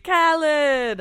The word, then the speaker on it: Khaled: That's not how you say his name. – It's DJ Khaled.